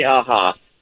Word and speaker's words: Aja: Is that how you pronounce his name? – Aja. – Okay. I say aha, but anyway.